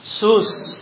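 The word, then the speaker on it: Sus (סוס): O, how do you pronounce it? Sus, tama, sus.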